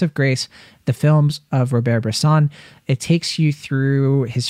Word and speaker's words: Robert Bresson: a brilliant essay about the films of Robert Bresson, or Robert Bresson. I always pronounce it in the Anglican fashion.